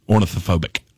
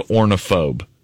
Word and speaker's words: ornithophobic: Ornithophobic. Ornithophobic. See, it's the extra syllable in the middle that yes. I. I just want to go ornithophobe. Nope.